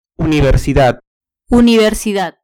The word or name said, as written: universidad